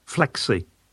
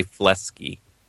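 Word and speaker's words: Fleksy: I always want to say Flexi, but somebody, what is it? – Flexi. – Flexi, that's it. – Yeah, Flexi. I always say Flesky instead of Flexi.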